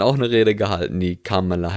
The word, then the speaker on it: Kamala: Die Vizepräsidentin, die Kamala Harris, wenn ich das jetzt richtig ausspreche, weil sie heißt ja nicht Kamala, sondern Kamala.